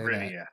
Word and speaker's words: Rivia: That's how you say Rivia.